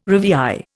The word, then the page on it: rivière: Indian